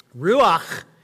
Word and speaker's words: ruach: Well, the Hebrew word that's used here for spirit is the Hebrew ruach. Ruach. And no, I'm not hawking a loogie, like that's how it's pronounced.